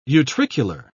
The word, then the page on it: utricular: British English